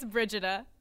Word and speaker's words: Brigida: It's Brigida.